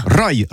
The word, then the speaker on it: Rai: Rai. Rai, ça s'écrit R-A-I. Rai.